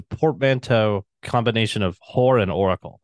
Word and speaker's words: Whoracle: And I was always like, is it pronounced Oracle or Horacle? It sounds like Horacle to me. And I think it was Scholes like, no, you mean Oracle? I'm like, no, it's Horacle.